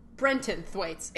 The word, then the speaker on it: Brenton Thwaites: Whoa, Brendan Thwaites is 27? Brenton Thwaites, excuse me. – Brenton. – I was saying it wrong.